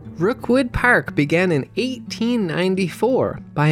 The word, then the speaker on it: Rockwood: One of these modest plots of land was named Rookwood, which is spelled like Rockwood, but St. Johners won't be very impressed if you don't pronounce it as Rookwood, and that's where the park's name came from.